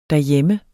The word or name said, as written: derhjemme